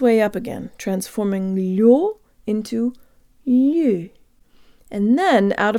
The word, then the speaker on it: лё: Now the first syllable. Let's imagine for a moment that the word consisted only of the л and the ю. It would sound like this. Лё, лё. The л is soft, so the tongue is high and forward. There's almost a y sound built in, and the tongue moves from very high to very low in order to pronounce the full stressed o sound.